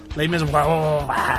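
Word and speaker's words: Les Misérables: That was the proper French. – The proper French is Les Miserables and then anything else. – Okay. – Les Miserables.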